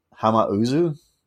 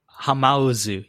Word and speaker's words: Hamauzu: His uh, fellow composers for uh, Final Fantasy X were Masashi Hamazu Hamauzu. – Hamauzu, yeah, ha- yeah, you said it right.